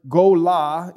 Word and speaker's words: golah: The exile word is golah in the Hebrew, and then I deported is galah in Hebrew for I deported.